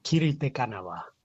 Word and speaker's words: Kiri Te Kanawa: Wie spricht sich Kiri richtig aus? – Kiri te Kanawa. – Kiri te Kanawa. War das so? – Jawohl.